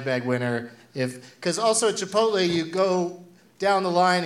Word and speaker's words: Chipotle: I call it Chipotle now because I heard someone mispronounce it as Chipotle. And it's Chipotle. The Chipotle gift card that Mark Norman put in the prize bag at the New York City show had $0 on it.